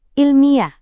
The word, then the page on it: ilmiah: Indonesian